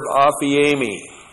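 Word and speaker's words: aphiemi (ἀφίημι): Aorist active indicative of the verb aphiemi. A-P-H-I long E-M-I, aphiemi.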